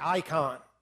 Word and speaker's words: eikon: It's pronounced the same way in the Greek, icon.